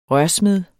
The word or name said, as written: rørsmed